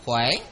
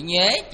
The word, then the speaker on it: nhuế: Cái chữ này cũng có, các vị cũng có thể đọc là chữ khỏe ha. Cũng là chữ nhuế, chữ khỏe. Nhưng mà chúng ta quen nhuế rồi ha.